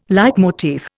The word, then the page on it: leitmotif: Indian